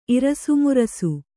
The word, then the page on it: ಇರಿಸುಮುರಿಸು: irisumurisu